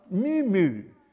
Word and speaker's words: muo: The mystes comes from a Greek word, muo, M-U-O. And this is the stem, mu mu. It's like a cow, doesn't it? But that's the way to pronounce it, I understand.